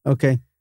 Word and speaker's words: hookah: That's a very odd pronunciation of the word. How? I know they spell it like that. I've seen H-O-O-K-A-H. But, I mean, this is like the in thing when I was in school. – Okay. – And everybody said oka.